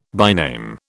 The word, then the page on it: byname: British English